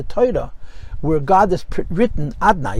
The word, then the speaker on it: אדני: And the Gemara says, Leike elam azeh elam haba, in this life, Hashem's name is written Hashem, and it's pronounced Adnay. And la'asad lavik fish aninichtav aninikra, it's written Hashem, and it's pronounced Adnay.